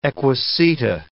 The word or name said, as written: equiseta